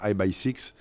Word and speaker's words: IBY6: Euh, humaine et amicale et, on a vécu de, plein de belles choses ensemble et l'autre grande aventure c'est avec I by Six qui s'écrit euh, IBY6, euh,